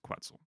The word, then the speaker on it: Quetzal: Pronounced Quetzal. – Quetzal. Someone was saying Quetzal, so I hate that pronunciation. I can look up the official pronunciation, but I'm pretty sure it's Quetzal.